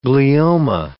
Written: glioma

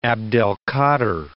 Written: Abdelkader

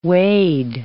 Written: wade

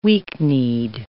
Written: weak-kneed

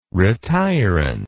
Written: retirant

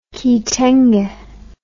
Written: kitenge

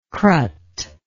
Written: crut

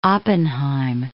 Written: Oppenheim

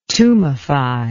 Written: tumefy

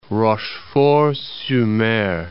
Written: Rochefort-sur-Mer

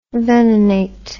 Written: venenate

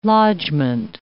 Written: lodgement

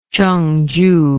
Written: chongju

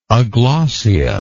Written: aglossia

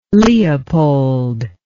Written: Leopold

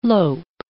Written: lope

